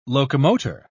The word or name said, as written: locomotor